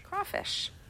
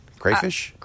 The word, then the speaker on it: crawfish: How do you say it? Crayfish? – Crawfish. – Yeah, crawfish. – Crawfish. Someone says crayfish? – It's spelled crayfish. – Wait. No. Crawfish is with a W. – Only when you pronounce it that way.